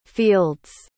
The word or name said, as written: fields